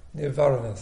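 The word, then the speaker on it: nīvaraṇa: I like the fact that the Pali word is niravanas. How do you pronounce it? Nirvaranas, that's right, I always get it wrong.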